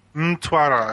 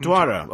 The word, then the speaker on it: Mtwara: Did I say it right? – Mtwara, I think. – Mtwara, okay.